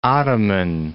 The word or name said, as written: Ottoman Empire